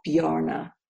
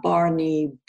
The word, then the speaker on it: Bjarne: So his name is spelled Bjarne. And the J is a silent. So it's Bjarne. And people call him Barney, Bjarne.